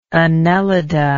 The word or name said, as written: annelida